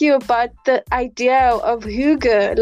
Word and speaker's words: hygge: Hygge. So it's spelled H-Y-G-G-E. Hygge.